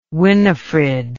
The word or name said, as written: winifred